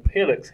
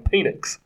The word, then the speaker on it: Penix: Because he wasn't saying Penix. He was saying Penix.